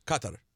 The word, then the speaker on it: Qatar: But I'm telling you, I got an email from the company, and it said the proper pronunciation is Qatar.